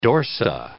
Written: dorsa

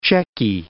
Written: Cechy

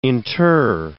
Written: inter

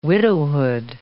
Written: widowhood

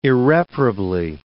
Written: irreparably

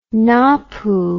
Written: napu

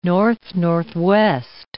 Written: north-northwest

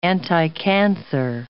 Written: anticancer

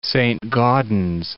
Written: Saint-Gaudens

